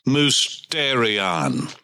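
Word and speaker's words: mystērion: The word in the Koine Greek text is musterion, M U S T E R I O N. The E has a pronunciation as a longy, as in they. Mysterion.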